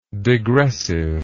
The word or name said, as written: digressiveness